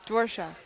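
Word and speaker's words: Dvořák: Dvorak. – Huh? – Dvorak.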